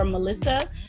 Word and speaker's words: Melissa: Melissa.